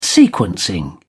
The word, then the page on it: sequencing: British English